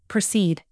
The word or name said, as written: precede